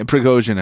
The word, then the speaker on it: Prigozhin: how do you say his name? Prigozhin? – Yeah, Prigozhin, I believe, yeah.